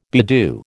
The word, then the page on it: bedew: American English